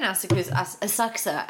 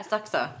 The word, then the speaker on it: Asakusa: Oh, I stayed in Asakusa actually is how it's said. – Is it? – Yeah, Asakusa.